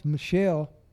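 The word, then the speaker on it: Michal: But the next one, her name's Micah, but we pronounce it, it's pronounced Michelle, and that was Saul's daughter that David married, David's first wife.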